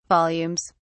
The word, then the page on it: volumes: British English